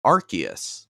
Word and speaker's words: Arceus: Pokemon has come out and it is now officially pronounced Arceus.